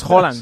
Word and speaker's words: Haaland: Es Haaland. – Sí, sí. Odegaard Haaland.